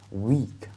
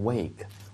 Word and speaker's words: week: 好，那么首先的话，首先我们掌握一个词的读音就是 week, W-E-E-K。大家一定要把口型收紧了，不要读成很松的、充斥的那种 wake。 Wake 的话是 W-A-K-E, 醒来那个词哈，发 A 的音是吧 ?Week, every week, OK, every week 是每周哈，每周。